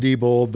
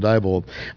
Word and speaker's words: Diebold: But of course, we all know them by the mispronounced name of Diebold. It's actually pronounced Diebold, but I refuse to. Since they killed our democracy, I'm going to call them Diebold.